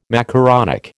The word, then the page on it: macaronic: American English